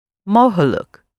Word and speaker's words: mogelijk: Mogelijk. – Mogelijk. So makkelijk and mogelijk. So the L-I-J-K ending, like you would say by itself, but at the end of words it's pronounced luck, in English can be translated very often as able, like in possible, mogelijk, yeah?